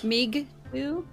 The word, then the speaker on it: Meegtwo: Meegtu? – Meegtu, yeah. They spell it Meegtwo.